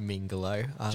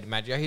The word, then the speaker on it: DiMaggio: You say John Mingolo. – John I'm pronouncing everything wrong. – DiMaggio.